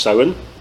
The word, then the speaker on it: Samhain: So the first one is actually tied into Halloween, which is in Irish pronounced Samhain.